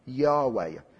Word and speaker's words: Yahweh: We haven't got the vowels, but we think that the name, God's name, his revealed name, is pronounced Yahweh.